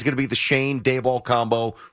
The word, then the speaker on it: Schoen: Is it Shane? – Not Sean. I thought it was Sean because it's spelled S-C-H-O-E-N. – I thought it was Sean as well. – Apparently, it's Shane. – It's Shane.